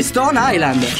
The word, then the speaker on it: Stone Island: Si chiama così, Stone Island.